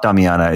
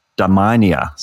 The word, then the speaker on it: Damania: It's not Damiana. It's Damania, Zubin Damania, and his friend Vinay Prasad.